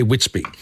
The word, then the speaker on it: WitsBB: Now, Whitsby, it's WITSBB. That's how it's spelt on the bottle and on the products, but you say Whitsby.